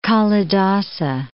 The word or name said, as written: Kalidasa